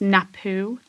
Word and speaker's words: Napue: And it's called, well, I think it's pronounced Napu gin. – Okay. – N-A-P-U-E.